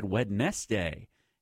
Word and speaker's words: Wednesday: Oh, there's a Wednesday isn't spelled like you think. You have to spell it out in your head, Wednesday. And that's how it's spelled.